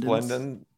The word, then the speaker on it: Resplendent: Pronounce that. Resplendence? – Resplendent. Yeah.